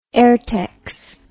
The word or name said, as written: aertex